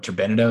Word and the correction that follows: turbinado: Is that not how you pronounce it? – No, turbinado. No. – Turbinado. There you go.